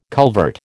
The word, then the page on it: culvert: American English